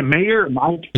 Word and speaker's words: Meijer: Did you guys, there's a grocery store called, I don't know how to pronounce it. It's spelled M-E-I-J-E-R. – Meijer. – Is it Meijer? – It's Meijer.